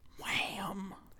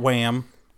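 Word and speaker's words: Wham!: Also, as Deadpool reminded us, it's pronounced Wham. Wham. Not Wham.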